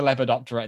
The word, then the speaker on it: Lepidoptera: I am so impressed at your pronunciation of Lepidoptera and Hymenoptera. – Thank you. – That rolled off your tongue, mate.